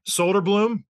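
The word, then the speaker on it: Söderblom: Elmer, I'm going to butcher it, Soderblom. – Yeah, that's worked.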